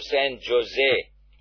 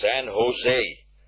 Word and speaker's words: San Jose: And Ronnie said, to San Jose. And the man said, sir, it's pronounced San Jose. San Jose. J is pronounced as H in the United States.